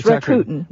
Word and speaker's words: Rakuten: All right, so this one is at Rakuten. I'm not sure I'm pronouncing it right. – Yes, Rakuten.